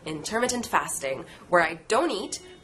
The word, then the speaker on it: intermittent: 네, 기본적으로 intermittent yeah. – fasting이라고 합니다. 미 부분에 강세가 있는 게 일반적이고.